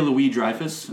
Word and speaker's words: Louis-Dreyfus: Julia Louis-Dreyfus.